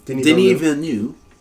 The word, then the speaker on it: Denis Villeneuve: Dune director, Denis Villeneuve. I don't know if I'm pronouncing his name right. – No, you are.